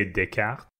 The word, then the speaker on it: Descartes: I'm assuming that's a French name. Like, am I saying that? Descartes? Like, how would you say that? – If it's French, I would say Descartes. – That sounds way more right.